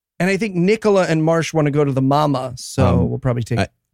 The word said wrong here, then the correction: MoMA: I think it's pronounced MoMA. – No, because it's like the mama, the mother of all museums. – Nope. – That's why not what MoMA means.